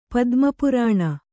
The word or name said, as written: ಪದ್ಮಪುರಾಣ